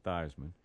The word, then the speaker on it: Theismann: But they thought, well, he could win that award if they changed the pronunciation to Theismann.